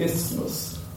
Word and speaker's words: isthmus: if you built a canal through the isthmus, that's how you say it, in Panama, America would become a superpower, because it would control both oceans.